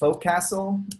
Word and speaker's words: Forecastle: Do you guys use something similar in your Yeah, we use it's pronounced Focastle, but or Focastle, but it's written Forecastle.